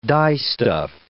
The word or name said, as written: dyestuff